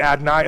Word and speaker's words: Adonai: So that gets real confusing when you're reading this in Hebrew because you read YHWH, you pronounce it Adonai, and then you come across the word Adonai and you pronounce it Adonai.